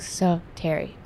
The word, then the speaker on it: Soteria: So it's pronounced like Soteria. – Yeah. – It's Soteria.